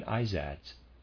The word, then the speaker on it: Izads: The Persians called the beings of the rank below the Amshaspads, Izads. Readers aside, that's how I'm going to pronounce that. I-Z-A-D-S, Izads.